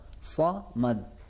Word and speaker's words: صمد: اسی لیے تھوڑا سا مشکل نہیں ہے، دیکھیں اللہ الصمد کے بجائے میں نعوذ باللہ اللہ الصمد، صمد صمد، منہ میں ہوا تھوڑی سی بھر جائے گی تو یہ حرف.